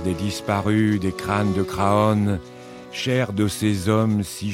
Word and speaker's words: Craon: La chanson de Craon, qu'il faut prononcer crâne, comme les crânes qui remontent encore, toujours et toujours à la surface.